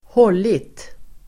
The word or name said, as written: hållit